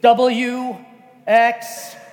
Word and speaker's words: Patalinghug: It's spelled PatalinghugX. Y, Z, and in my native Philippine language, it's pronounced Smith. So you should have no trouble. No, actually, it's just like it's spelled. Patalinghug.